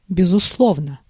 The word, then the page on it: sûrement: [syʁ.mɑ̃]; France